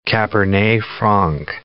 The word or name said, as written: cabernet franc